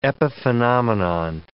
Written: epiphenomenon

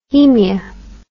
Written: haemia

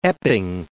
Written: Epping Forest

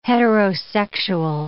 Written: heterosexual

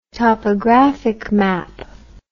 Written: topographic map

